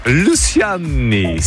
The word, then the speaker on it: Luciani: Clara Luciani. Je prononce mal. Luciani. – Juste pour rire, allez-y, comment? – Luciani, si. – Voilà, tout à fait.